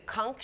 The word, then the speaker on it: conch: Brady even using a conch shell. Conch shell, like rhymes with Gronk, I guess, to summon Gronk out of his first retirement to join him in Tampa Bay.